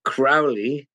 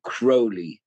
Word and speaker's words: Crowley: It's actually Crowley, not the same as Alistair, who pronounced his name Crowley. – Right. – Crowley is an English name. Mine is an Irish name with a totally different origin. Comes from Gaelic.